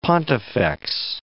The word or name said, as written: pontifex